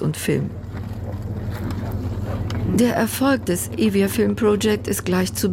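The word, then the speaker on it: Evia (Εύβοια): Mit diesen Worten des Songs Kalokeri, Sommer, des Musikers Dionysios Savopoulos im Ohr, erreiche ich die zweitgrößte griechische Insel Euböa, im Griechischen einfacher Evia ausgesprochen.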